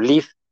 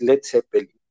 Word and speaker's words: Led Zeppelin: la quitaron para que solo dijera LED, como para que no se pronunciara como Lid, porque decían, pues quien no hable inglés a lo mejor lo va a pronunciar como Lid y pues no se pronuncia así. Entonces, pues como que por algo fonético dijeron, vamos a quitarle la A para que todo el mundo lo diga como es Led Zeppelin.